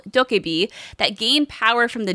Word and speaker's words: DokeV: DokeV. – DokeV. – Okay, it's like Tokapri. Tokapri from Pokemon.